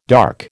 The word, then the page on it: dark: American English